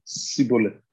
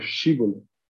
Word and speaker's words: Shibboleth: But if he couldn't pronounce the H and said Shibboleth, instead of Shibboleth, he was dragged away and killed.